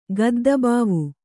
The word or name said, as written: ಗದ್ದಬಾವು